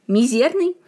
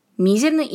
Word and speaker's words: мизерный: Несмотря на то, что солидные словари до 2000 года утверждали только один правильный вариант с ударением на второй слог – мизерный, современные авторитетные издания считают оба варианта равноправными. Мизерный имеет место быть.